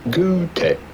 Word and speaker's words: Goethe: In German, his name would be pronounced something along the lines of Goethe.